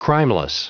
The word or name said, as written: crimeless